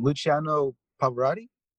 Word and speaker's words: Luciano Pavarotti: Luciano Pavarotti? – Yeah.